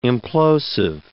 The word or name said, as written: implosive